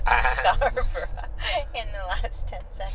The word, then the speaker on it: Scarborough: Listen to that pronunciation. Scarborough. – Proper Scarborough. – Scarborough.